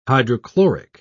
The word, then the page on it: hydrochloric: British English